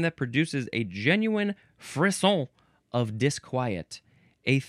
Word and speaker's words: frisson: Moving on to frisson. F-R-I-S-S-O-N. Pronounced frisson.